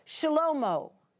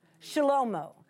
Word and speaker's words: Shlomo: In Hebrew, Solomon's name would be pronounced, listen carefully to it, Shlomo, Shlomo. Not Solomon like we would say it, but if we were Hebrew and we were saying it like they would have said it, it would have been Shlomo.